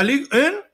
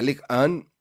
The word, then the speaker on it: Ligue 1: Este, es demasiado lo que invirtió, para lo poco que ha cosechado, porque la Ligue 1 la gana de todas maneras, o por lo menos la gana asiduamente, ¿no? – Sí, la Ligue 1 como bien lo pronuncias y lo dices, siempre, siempre la, la, van a ganar.